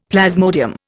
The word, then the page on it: plasmodium: Indian